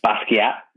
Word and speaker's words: Basquiat: Uh, Basquiat is buried there. That's how you pronounce the name, right? The artist Basquiat. Yeah.